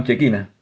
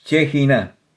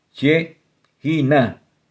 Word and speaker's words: Shejiná: Muchos, muchos la llaman Chequina. Se pronuncia Chejina. Chejina. Es la pronunciación correcta.